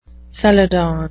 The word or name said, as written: celadon